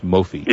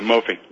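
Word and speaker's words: Mophie: I guess it's pronounced Mophi. – Yeah, Mophi.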